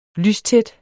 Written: lystæt

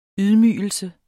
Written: YDMYGELSEN

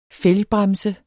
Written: fælgbremse

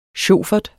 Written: sjofert

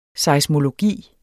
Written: seismologi

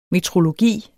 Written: metrologi

